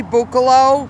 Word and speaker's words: Bucolo: My name is Cindy Bucolo, B-U-C-O-L-O. My mother, Judy Sawicki, S-A-W-I-C-K-I, has been a resident here for 7 years, and I have seen the care go downhill.